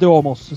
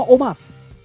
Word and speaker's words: Omos: Siete voi oh. che lo chiamate Omos. Si chiama Omas. – Omas la, la, pronuncia americana.